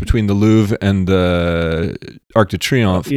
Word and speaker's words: Arc de Triomphe: I had, I saw a photo around New Year's of, I think it was the Eiffel Tower or the Arc de Triomphe. I said that right. – Arc de Triomphe.